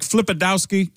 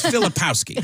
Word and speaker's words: Filipowski: He's like, Flipadowski? It's Filipowski. Filipowski.